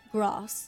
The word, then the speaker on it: grass: How would you say grass? With a long A, so grass, or with a short A, grass? Okay, so we're Southern, so that's down to, like, yeah, where in England you're from. And I'd say the first one with a long A, grass.